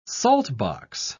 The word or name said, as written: saltbox